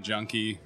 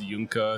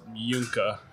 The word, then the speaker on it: Juhnke: And in German, the J makes a Y sound, and then when there's an E at the end of a word, it makes kind of an sound. So it's Junka, if you're familiar with German pronunciation.